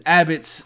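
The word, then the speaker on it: Arceus: Well, Pokemon Legends Abbots, I think, will... What? Am I saying that wrong? – No, that's right. That's right.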